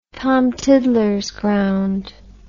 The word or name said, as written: tom tiddlers ground